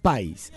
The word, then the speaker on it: Pais: estos autocorrectores con los que por ahí redactamos y escribimos, hay que estar bastante atentos porque no es con tilde en la I, es con tilde, o por lo menos la acentuación del apellido de Ernestina, la periodista fallecida, es Ernestina Pais. Esa es la pronunciación correcta del mismo.